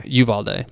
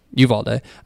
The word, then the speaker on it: Uvalde: If I'm praying for, you know, the people of Uvalde, am I saying that right? – Uvalde. – Yeah. Okay. Uvalde.